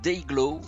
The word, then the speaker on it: DYGL: pour info, le groupe donc cela s'écrit DYGL, on prononce Dayglow.